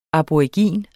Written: aborigin

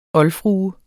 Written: OLDFRUERNES

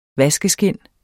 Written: vaskeskind

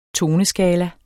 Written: toneskala

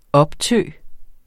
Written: optø

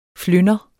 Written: flynder

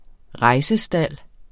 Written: rejsestald

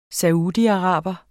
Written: saudiaraber

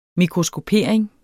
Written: mikroskopering

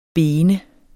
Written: bene

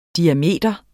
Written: diameter

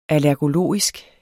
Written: allergologisk

